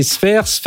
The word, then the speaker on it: Sfeir: Didier, que vous avez peut-être aperçu, il était venu dans une émission parler de développement, justement l'avenir des développeurs, directeur général chez Sphere. Alors, pour ceux qui nous écoutent à radio, ça s'écrit S-F-E-I-R.